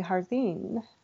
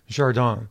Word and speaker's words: Jardine: And then she did a workshop with Valerie Jardine. I always want to say her name like Spanish, but she's not Spanish. – That's right. She's French. Jardin.